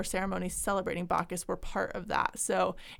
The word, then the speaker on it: Bacchus: Is it pronounced Bacchus? – Yeah.